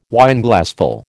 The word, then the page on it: Wineglassful: American English